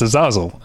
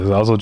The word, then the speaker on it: Azazel: One of the fun things about going to festivals with the directors are there um, is uh, learning how to pronounce their names because they get introduced and they're there, and it's Azazel.